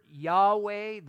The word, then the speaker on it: Yahweh: Now you say, well, I've heard Yahweh. That is a possible pronunciation.